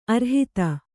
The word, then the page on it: ಅರ್ಹತೆ: arhate